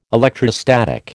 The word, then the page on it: electrostatic: American English